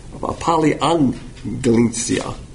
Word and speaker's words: paliggenesia: The word for regeneration is paligenesia, which is P-A-L-I-G-G-E-N, paliangelesia. That's a tongue twister. P-A-L-I-G-G-E-N-E-S-I-A. When you got a double G, it's pronounced as an N.